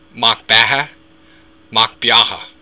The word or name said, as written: macbeatha